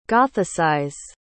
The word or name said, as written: gothicize